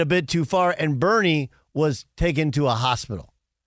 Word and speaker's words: Burnie: But it's spelled B-U-R-N-I-E. Right, because it's Heat, so it's Bernie.